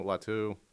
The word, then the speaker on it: Latu: So I'm going to stop the slide of Latu from UCLA. Is that how you say his name? – Yes. – Latu.